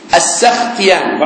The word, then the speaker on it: السختياني: عفوا، قال أبو عبيد حدثنا إسماعيل بن إبراهيم، الأثر نسيناه، قال أبو عبيد حدثنا إسماعيل بن إبراهيم عن أيوب بن أبي تميمة السختياني، هذا هو الصحيح في نطقه بفتح السين السختياني، بعض الناس يقول لك بنصب السين، هذا غلط، النصب حالة إعرابية، وإنما الفتح حركة للحرف في موضعه من الكلمة، فلا نقول هذا حد.